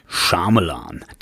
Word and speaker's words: Shyamalan: Night Shyamalan. Das ist übrigens tatsächlich die von mir überprüfte korrekte Aussprache dieses Namens.